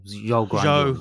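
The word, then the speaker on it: Zhou Guanyu: How are we saying his name? – Guanyin Zhou. Well, Zhou Guanyin.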